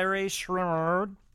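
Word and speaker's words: Rae Sremmurd: over on the Billboard Hot 100, Black Beatles by Ray Schreier is still number one. That's how you pronounce it, yeah.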